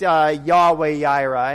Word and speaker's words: Yahweh Yireh: But another pronunciation of that is just Yahweh Yireh.